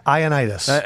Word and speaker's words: Ioannidis: And the guy we got to give a little This I don't know if I could say his name right. Iodonis? – Matt Ioannidis.